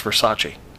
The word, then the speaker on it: Versace: He goes, no, it's pronounced Versace.